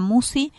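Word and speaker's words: Musci: El canal de YouTube en el que quedan los programas lleva mi nombre, Marisa Musi. La lista de reproducción. – Chito, ¿cómo se escribe Musi? – M-U-S-C-I. S-C-I.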